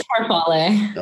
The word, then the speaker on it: farfalle: It's farfalle.